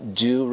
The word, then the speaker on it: due: Aí a Becky fala, with all due respect, com todo o devido respeito, essa palavra aqui, do, D-U-E, pronuncia do, quer dizer devido, com todo o devido respeito, Pedro, I think a rally is the best way to do that.